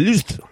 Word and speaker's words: Lysz: Hay un comentario súper largo de una persona, List, L-Y-S-Z, esto se pronuncia List, ¿vale?